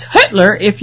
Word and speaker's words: Hüttler: And then the other is Hüttler, which it's Hüttler, but it's spelled H-U with an umlaut, T-T-L-E-R. And when it's said, it sounds a lot like Hitler.